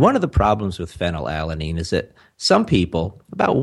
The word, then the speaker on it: phenylalanine: So you're talking about phenylalanine, which is an amino acid that that makes up the artificial sweetener aspartame.